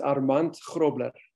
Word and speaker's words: Armand Grobler: Okay, that is the Australian or English pronunciation, but there is a South African pronunciation in case anyone wants to know. Go ahead, Armand. – Yes, it's pronounced Armand Grobler.